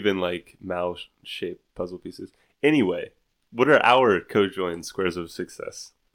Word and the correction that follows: conjoined: It's conjoined. – Conjoined. – Conjoined, not co-joined. – Not co-joined. – Is co-joined a word? – That's how it's spelled, right? – No, there's an N.